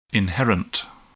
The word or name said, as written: inherent